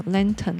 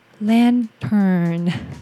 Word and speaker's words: lantern: 然后 lantern 就是要先卷舌，然后再加鼻音。我好像本来都念什么 lantern lantern，后来就是听了自己的发音之后，就发现说 OK，先卷舌再加鼻音 lantern。